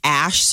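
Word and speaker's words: Æ: In Scandinavian, it's a part of their alphabet, and it's pronounced Ash. So some people are wondering if the name is X Ash Archangel.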